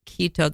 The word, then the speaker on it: Quito: You have lived also in Quito, that's Ecuador. I'm still not saying that right? – Yeah, you said it right.